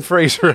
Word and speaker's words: Fraser: For some reason, people keep putting an I in his last name. It's not there. It's Fraser. It's a soft S. It's not a sh.